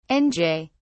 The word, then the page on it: nj: British English